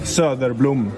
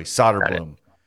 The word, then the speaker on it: Söderblom: Söderblom.